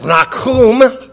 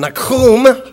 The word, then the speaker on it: Nahum: I'm going to pronounce it in Hebrew, but I'm not going to do it anymore after I do this, because I don't want to do it anymore. But it's Nahum.